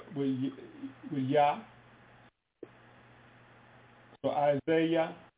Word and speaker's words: Yah: But in the English, it's spelled with a J? – Right. – Okay, and it's just Yah. That first one is telling you, the first part is telling you how it's spelled. The second part is telling you how it's pronounced. So you see Y-A-H-H? – Right. – Yah. And then comma, Y-A-W? – Right. – Okay, that's just how it's pronounced. Yah.